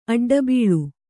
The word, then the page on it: ಅಡ್ಡಬೀಳ್: aḍḍabīḷ